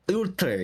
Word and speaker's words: Uther: The code name for this one is Project Ulster? Is that how you pronounce it? Ulter, yeah, Ulter, UTHER.